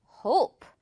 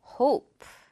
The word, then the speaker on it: hope: 바라다, 희망하다라는 뜻인데 이 단어를 hop 이렇게 발음하는 친구들 정말 많아요. 생각보다 정말 많고 hop, hoop 이렇게 짧게 발음하는 다양한 실수들이 있어요. 정답은 어떻게 발음해야 돼요? 그렇죠. 이돌 뛰어넘는 굴곡진 소리로 hope 이렇게요. hope. It's not hop or hoop 이렇게 짧지 않아요?